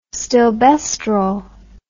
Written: stilbestrol

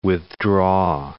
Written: withdraw

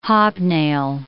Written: hobnail

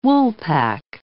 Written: woolpack

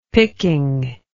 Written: picking

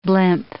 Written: blimp

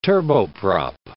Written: turboprop

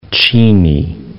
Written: Cheney

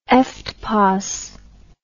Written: eftpos

